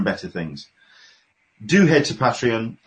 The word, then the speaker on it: Patreon: You started up your Patreon. Am I pronouncing it right? – Yes, I have.